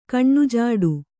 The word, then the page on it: ಕಣ್ಣುಜಾಡು: kaṇṇujāḍu